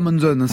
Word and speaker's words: Monzon: Béatrice Uria Monzon, c'est comme ça qu'il faut prononcer. Monzon, ouais.